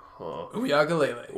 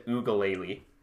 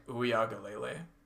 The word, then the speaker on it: Uiagalelei: Hearing that for Hawaiian names, you're supposed to pronounce every single vowel. – Okay. – So it's like Uyagalele.